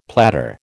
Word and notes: platter: American English

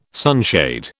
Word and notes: sunshade: British English

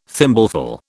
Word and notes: thimbleful: British English

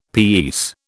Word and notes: pease: British English

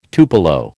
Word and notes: tupelo: American English

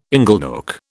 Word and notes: inglenook: British English

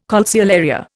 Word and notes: calceolaria: Indian

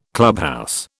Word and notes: clubhouse: British English

